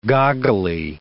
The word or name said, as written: goggly